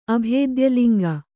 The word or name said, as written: ಅಭೇದ್ಯಲಿಂಗ